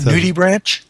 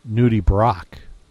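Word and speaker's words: nudibranch: But I guess the proper pronunciation is Nudie Brock.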